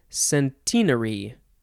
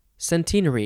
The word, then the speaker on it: centenary: But it looks like the British say centenary, centenary, because of course they do.